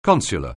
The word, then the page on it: consular: anglais